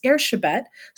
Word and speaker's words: Erzsébet: And Elizabeth in Hungarian is Erzsébet.